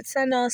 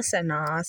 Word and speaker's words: Seno: Seno, Seno.